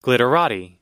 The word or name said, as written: glitterati